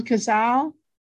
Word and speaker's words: Cazale: How do you say his name? John Cazale? – Yeah.